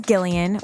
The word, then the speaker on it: Gillian: but my real name is Gillian with a hard G.